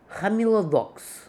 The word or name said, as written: Hamilodox